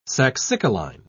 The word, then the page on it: saxicoline: British English